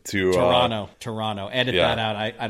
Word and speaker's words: Toronto: Toronto. I can't believe I pronounced the T. That is, I should get docked major points for that. That was bad.